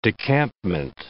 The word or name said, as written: decampment